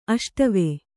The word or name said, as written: ಅಷ್ಟವೆ